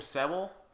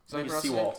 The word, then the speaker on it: Sewell: I think you pronounce the name Sewell. – Sewell.